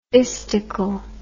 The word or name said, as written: istical